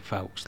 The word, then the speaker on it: Fawkes: ¿Cómo se pronunciaría? – Fawkes, no sé. – Fawkes. – No sé, porque no, ni siquiera he leído ese tipo de titular. Fawkes. – Pues sí, Fawkes, tío.